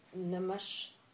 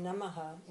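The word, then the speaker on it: namaḥ: So we say Om, and then Namash, from Namaha, when we do the sandhi, it becomes Namash Shivaya. Namaha means my salutations. Shivaya is in the dative case, and the root Namaha, the indeclinable Namaha, takes the dative, which is Shivaya.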